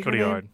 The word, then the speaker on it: Cotillard: Marion Cotillard, I think is how you say Cotillard. – Her name. Cotillard. – Yeah. Cotillard.